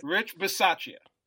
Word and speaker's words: Bisaccia: Rich Bisaccia.